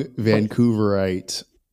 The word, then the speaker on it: Vancouverite: If you were to play a round of golf with the younger version of yourself, maybe that young Vancouverite. How do you say that? – That is correct. That is correct. Vancouverite.